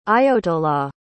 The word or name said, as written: ayotollah